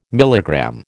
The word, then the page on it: milligram: American English